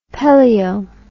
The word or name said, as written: Pelliot